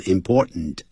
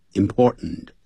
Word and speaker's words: important: 这个情况 S-I-T-U-A-T-I-O-N Situation 就这样 It is important 这个 important 我常讲念的时候念成 important 不要念成 important 那个 t 用鼻音出来 important It is important for a couple 他说情侣啊有必要有这个重要性有必要干什么呢 To have something in common hold the center with each other.